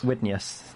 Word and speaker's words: Widenius: Widenius. That would be how you pronounce that.